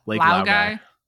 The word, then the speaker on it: Laogai: This week, we watched episode 37, Lake Logai. Is that how it's pronounced? – Laogai. Lake Laogai.